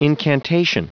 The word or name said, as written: incantation